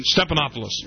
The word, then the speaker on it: Stephanopoulos: And George Stephanopoulos, I always pronounce his name wrong on Sunday morning. – Stephanopoulos.